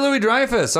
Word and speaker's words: Louis-Dreyfus: Man, I always have a tough time pronouncing her name correctly, but Julia Louis-Dreyfus? – Julia Louis-Dreyfus.